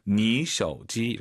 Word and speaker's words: 你手机: 삼성 삼성이니까 왕 선생님처럼 니 올라가야죠. 네, 쇼 올라가면 안 되죠. 뒤에 일성이니까 이 쇼는 반삼성이에요. 你手机 이렇게 연습하세요.